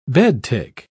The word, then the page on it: bedtick: British English